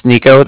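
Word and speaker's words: Niko: Again, my name's Nico. That's N-I-K-O. Uh-huh. And the Bank of Cardiff is spelled C-A-R-D-I.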